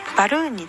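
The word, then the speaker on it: balloon: バルーンについてですが、バルーンの L はねっとり発音します。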